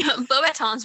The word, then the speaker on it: Beauxbatons: Beauxbatons is one of the few French words I can actually say properly.